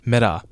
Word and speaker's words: Meta: 好，那讲到这里呢，大部分人应该猜到了，没错，就是脸书 Meta，或者是你用比较美式的发音 Meta。那大家最近会这么热烈的讨论 Meta 呢？当然就是因为他们在两个礼拜前呢进行了他们的开发者大会 Meta Connect，然后在 Meta Connect 之后呢，也有两篇跟祖克伯的这个长时间访谈上长时间访问上架。